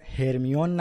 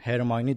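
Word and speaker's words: Hermione: تلفظات دقیقا مثل نسخه بریتانیاییه. یعنی ما دیگه هرمیون نداریم، هرمیونی داریم.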